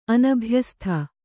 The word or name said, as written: ಅನಭ್ಯಸ್ಥ